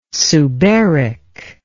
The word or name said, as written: suberic